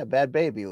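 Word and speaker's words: Bhad Bhabie: Yeah, Bad Baby, Luke. That's how you pronounce the girl who was like, catch me outside.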